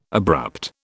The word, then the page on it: abrupt: British English